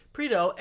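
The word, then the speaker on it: Prideaux: And what happens is Control picks up this guy, Jim Pardue. Am I saying that right? – Jim Prito.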